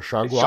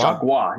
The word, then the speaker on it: Chargois: Chargois uh, or Chargois. Chargois, yes, thank you.